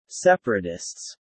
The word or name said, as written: separatists